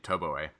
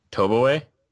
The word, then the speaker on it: Toboe: So like for example, my dog's name Toboe. People aren't used to that O to A sound, so it's hard for people to like say her name and understand what I'm saying. – Toboe.